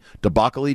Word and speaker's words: debacle-y: Debacle-y dumb decision that was. – Did you just say debacle-y? – Debacle-y. It rhymes with broccoli.